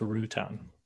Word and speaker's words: Routan: It's pronounced Rutan. – Rhymes with crouton.